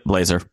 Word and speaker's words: Blazor: And then rather than taking a shot with Blazor, is that how you say it? Probably saying that wrong, because you know me. – Yep, Blazor.